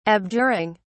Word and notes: abjuring: British English

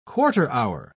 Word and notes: quarter-hour: British English

